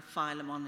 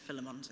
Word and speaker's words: Philemon: And apparently Philemon is supposed to be right, but also Philemon's okay.